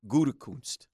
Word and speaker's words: Gutekunst: There's no N in there, so it's Gut, Gutekunst.